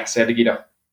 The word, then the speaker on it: San Diego: San Diego? – Yeah, San Diego.